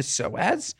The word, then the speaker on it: psoas: The psoas.